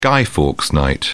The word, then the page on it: Guy Fawkes Night: British English